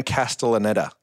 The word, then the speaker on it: Castellaneta: Dan Castellaneta.